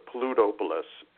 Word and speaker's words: Plutopolis: I also just want to point out, I goofed up the pronunciation of the first sound animated feature. It's called Plutopolis, and it's been a while since I've thought about that, but it's...